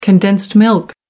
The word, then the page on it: condensed milk: American English